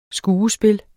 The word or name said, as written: skuespil